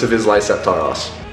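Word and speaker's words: Hvis Lyset Tar Oss: But the most common way that I've heard it pronounced is Hvis Lyset Tar Os.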